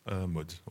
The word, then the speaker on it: Mods: Mutt. Mutt, je ne sais pas comment on prononce. – Comment ça s'écrit ? – S-L-E-A-F-O-R-D-M-O-D-S. – Mods, ouais, du coup, Mods.